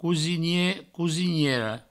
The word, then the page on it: cosinier: cosiniera; cousinie; cousiniero